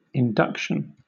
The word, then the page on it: induction: British English